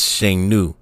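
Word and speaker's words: Shang Nu (剩女): Recently, feminists in China changed the original meaning of leftover woman into victorious woman, but retained the pronunciation as Shang Nu.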